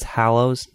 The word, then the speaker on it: Hallows: It's Hallows, not Hollows. – Hallows. Deathly Hallows. Finally, after like 36 chapter by chapters, I say it right.